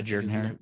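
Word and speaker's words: Jordan-Hare: Yeah, Jordan Hare. Yeah, they pronounce it Jordan Hare, even though it's spelled Jordan.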